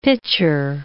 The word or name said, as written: pitcher